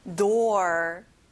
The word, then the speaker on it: door: 그 다음은 door, 문이에요. 친구들, 혹시 floor라는 단어 기억나나요? o가 두 개인데 u 소리가 안 나고 or 소리를 내야 돼요. door.